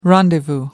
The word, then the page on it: rendezvous: American English